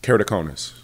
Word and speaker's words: Keratoconus: First of all, how do you say the condition properly? What's the proper pronunciation? – Keratoconus.